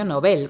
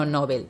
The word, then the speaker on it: Nobel: Para terminar, recordamos que Peter Higgs ganó el premio Nobel con pronunciación aguda, como se recomienda, no Nobel, de física en 2013.